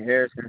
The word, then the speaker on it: Harrison: Devon Harrison. – Can you spell that for me? – First name Devon is spelled D-E-V-O-N. Last name Harrison is spelled H-A-R-R-I-S-O-N.